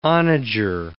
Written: onager

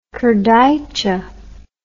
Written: kurdaitcha

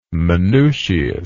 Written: Manutius

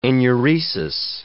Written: enuresis

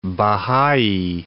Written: Baha'i

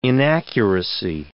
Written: inaccuracy

